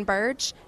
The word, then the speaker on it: Burge: I'm on Facebook just by my name, Lauren Burge, and I'm on Instagram with skeet_shooter686. – Very good. And Burge is spelled B-U-R-G-E.